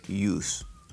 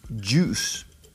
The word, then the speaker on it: juice: Mucha gente dice use y es juice. Juice.